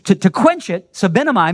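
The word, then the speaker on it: sebenomai (σβέννυμι): It's pronounced sebenomai. That's the Greek word, sebenomai. And that word quench, the word sebenomai, the Greek word, it only ever refers to fire, except in this one case.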